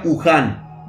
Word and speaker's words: Wuhan: Se pronuncia Wuhan.